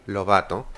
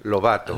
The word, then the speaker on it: Lovato: Demi Lovato. Will offer. – How do you say it in Spanish? – Lovato. – Okay. – In English, Lovato.